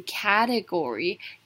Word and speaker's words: category: 我认为呢，她的离职呢，其实代表了她不再信任这个产品的走向。最后面来看的是 category.